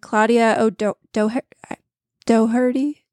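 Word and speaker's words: Claudia O'Doherty: And this is a half an hour comedy with Craig Robinson and Claudia Doherty. I'm so bad at pronouncing names. I'm so sorry. – You nailed it.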